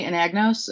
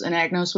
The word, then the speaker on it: Anagnos: Follow me on Twitter at Chloe Anagnos, Anagnos with an A, or if they just go to your Twitter profile, they can find me.